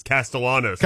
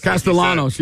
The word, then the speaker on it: Castellanos: And I remember him because, you know, Nick Castellanos is in Major League Baseball. That's how it's pronounced. – Right. – But he pronounces the L's. It's Castellanos. – Castellanos, yeah.